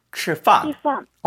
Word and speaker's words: 吃饭: 근데 보통 이게 발음이 자꾸 치로 될 때가 있거든요. 네. 으 발음으로. 치. – 吃饭 잘했어요.